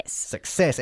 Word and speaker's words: success: Success, enfatizando la segunda sílaba. Success. Bien.